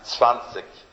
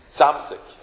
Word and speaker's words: Zwanzig: When he got to 20, he just couldn't pronounce Zwanzig. And he said, Zamzig. Avram Zamzig. He said, Zamzig, Zamzig. He couldn't for the life of him pronounce Zwanzig, which is 20 in Yiddish.